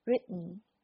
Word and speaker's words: written: 音爆出来直接这个你 t 上去的时候，舌尖碰上牙床，然后就待在那儿就不动了，然后通过鼻音出的这个音，因为 n 本身就是这样发的，所以当你发出来的时候就是 written。大家注意呢，这个 t 并不是完全省略掉，如果完全省略掉的话，我们就变成 run。 run，如果你把这个发音这样写的话，其实是这个 t 要 hold 住，然后直接发 n 的这个音，written。好，